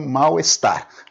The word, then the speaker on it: mal-estar: Mas você pode pronunciar, e até melhor que pronuncie, malestar. Ela teve um malestar súbito hoje, um malestar. Você pode pronunciar malestar ou então malestar tudo junto. É preferível que você pronuncie tudo junto, mas grafar nunca, sempre separado.